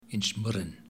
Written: Inchmurrin

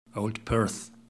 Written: old perth

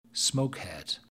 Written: smokehead

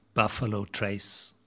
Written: Buffalo Trace

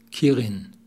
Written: Kirin

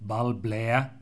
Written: Balblair

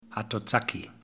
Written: Hatozaki

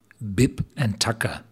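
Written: Bib & Tucker

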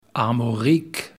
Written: Armorik

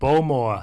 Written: Bowmore

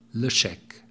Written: Ledaig

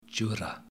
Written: Jura

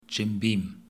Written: Jim Beam

